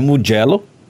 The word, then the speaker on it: Mugello: Temos também a possibilidade de uma corrida em Mugello, para alegria, ou em Mugello, falar né? bem a pronúncia correta do italiano e também em Imola.